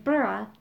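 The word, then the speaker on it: burgh: Now no, it's pronounced with a Scottish burr. It's pronounced burra, not berg.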